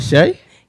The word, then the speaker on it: Shay: Am I saying that right, Shay? – Yep, like the butter.